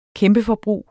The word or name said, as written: kæmpeforbrug